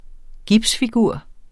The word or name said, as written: gipsfigur